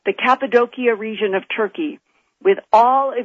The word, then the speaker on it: Cappadocia: known as Cappadocia. That is the way it's pronounced in Turkey.